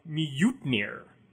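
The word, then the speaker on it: Mjölnir: June says, among other things, the J in Scandinavian tongues is pronounced like an I. So I say Mjolnir. – Yeah, okay, that sounds a little more Norse than Mjolnir. – But somebody much more learned than me once told me it was more properly something like Mjutnir in Old Norse.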